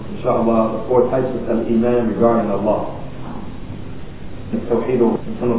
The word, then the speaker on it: Al-Iman: And I spelled it like this for a reason, so that we can learn how to pronounce it for those of us who have not studied Arabic. We can try and get as close as we can to the way it sounds in Arabic by looking at it spelled like this in English. Al-Iman.